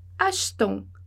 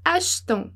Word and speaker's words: achetons: Então, você perceba que o e, ele não tem acento mais. Então, quer dizer que ele não vai ser pronunciado. Então, a gente vai dizer achetons. Achetons, ok?